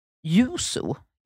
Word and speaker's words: Yuzu: Och jag vet inte riktigt hur man uttalar det här. Yuzu. Mm. Y-U-Z-U. – Låter rätt.